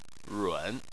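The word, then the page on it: run: tone 3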